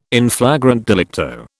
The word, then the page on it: in flagrante delicto: British English